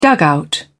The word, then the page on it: dugout: British English